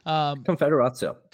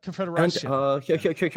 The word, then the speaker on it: Konfederacja: Now, you mentioned Konfederacja earlier, or however you say that. I'm not great with the pronunciation. – Konfederacja. – Konfederacja.